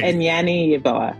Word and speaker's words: Anyane-Yeboa: Anyane-Yeboa.